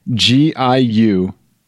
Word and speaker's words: Giulietta: GIULIETTA.